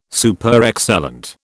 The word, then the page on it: Superexcellent: British English